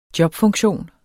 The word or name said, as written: jobfunktion